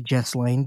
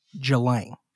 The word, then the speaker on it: Ghislaine: Now, first of all, I say Ghislaine because there's an S in there, and I want to recognize that S. But I do think that the correct pronunciation is Ghislaine. Ghislaine.